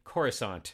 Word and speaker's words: Coruscant: And I don't know what I was doing, but I pronounced it Coruscant with a hard C. – Okay. – Coruscant. And then the prequel comes out and they say Coruscant.